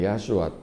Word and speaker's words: ヤシュア: ヤシュアって、ヤシュアっていうのはイエスの正しい発音ですね。